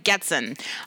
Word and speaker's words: Goetzen: And so first up is Brianna Goetzen. Did I say your last name correctly? – It's pronounced Goetzen. – I'm sorry. It's Brianna Goetzen from Minnesota.